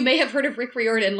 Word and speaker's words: Riordan: You both mentioned not having heard of the book, but you might have heard of Rick Riordan, who did the Percy Jackson series.